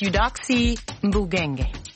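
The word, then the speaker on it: Eudoxie Mbouguiengue: I had to look it up. It's Eudoxie Mbugenge.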